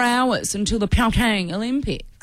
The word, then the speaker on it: Pyeongchang: Pyeongchang.